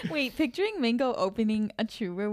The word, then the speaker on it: Churu: Churro, but we just call it churro in our household. – Is it churro? – It's spelled C-H-U-R-U. Churro.